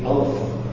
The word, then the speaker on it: alpha: It's actually alpha. Can you read that up there on the screen? Everybody read it? No? Okay, put it down further down the middle. Okay. Alpha. As the A in father.